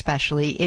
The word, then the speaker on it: especially: I said that the word especially does not have an X in it, so it is not pronounced X. Especially, it is especially, E-S-P-E-C-I-A-L-L-Y, especially.